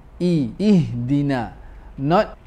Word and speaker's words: ihdina: Make sure to pronounce ihdina with complete kasrah. Ihdina, not ihdina.